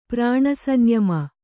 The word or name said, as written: ಪ್ರಾಣಸಂಯಮ